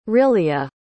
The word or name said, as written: rillia